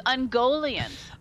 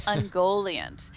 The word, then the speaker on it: Ungoliant: I'm sorry. It's Ungoliant. – Ungoliant, right. – Not Ungoliant. I always pronounce that wrong. It's Ungoliant.